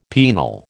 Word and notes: penal: American English

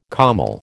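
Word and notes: kümmel: American English

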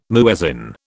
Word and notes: muezzin: British English

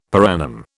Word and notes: per annum: British English

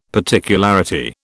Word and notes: particularity: British English

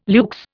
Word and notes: luxe: Indian

